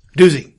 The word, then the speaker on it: doozy: It's quite the daisy. Doozy. Doozy. – Oh yeah, it's doozy. That's how you say it.